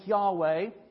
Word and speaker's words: Yahweh: God's covenant name, the name He revealed to Moses at the burning bush, we usually pronounce that name Yahweh.